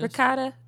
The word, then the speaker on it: ricotta: Ricotta.